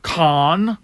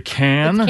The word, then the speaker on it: Cannes: Cannes? – It's pronounced Cannes. – Is it Cannes? – Cannes. – You rube.